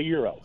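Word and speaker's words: gyro: How do you say it in the right way? – You hit it on the button. It's a euro.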